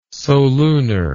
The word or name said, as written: solunar